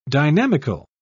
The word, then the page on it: dynamical: British English